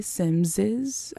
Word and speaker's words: Simses: It came out 2016, and it is based off of a novel written by Mary Simms, I believe her name is pronounced. It's spelled S-I-M-S-E-S.